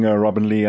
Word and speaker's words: Robyn-Leigh: Thank you for confirming, Robyn-Leigh.